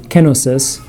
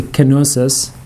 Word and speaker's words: kenosis: It's really, in Greek, you emphasize the first syllable, so it would be kenosis, but a lot of times people say kenosis, like theosis, right?